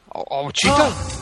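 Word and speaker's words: Ouachita: Travis Keene, 28, was busted on an obscenity charge and booked into the, I don't even know how to pronounce this, Awachita. Awachita? Yeah, that's how you pronounce it.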